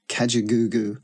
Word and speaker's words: Kajagoogoo: Hi, I'm Lamal, ex-lead singer, songwriter of 80s British band Kajagoogoo. Now, if you're hearing that name for the first time, it's spelled K-A-J-A-G-O-O-G-O-O, Kajagoogoo. Enjoy saying it.